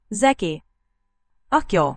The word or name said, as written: zekiakyol